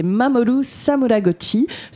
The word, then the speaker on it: Mamoru Samuragochi: C'est Mamoru Samuragochi, surnommé bien évidemment le Beethoven japonais. – Très belle prononciation pour le, nom, le nom japonais, très belle prononciation, bravo.